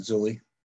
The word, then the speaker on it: Zeuli: Yeah, it's correct, Zeuli.